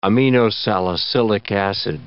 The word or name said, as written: aminosalicylic acid